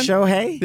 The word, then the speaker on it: Shohei: Shohei? – There you go.